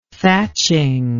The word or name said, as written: thatching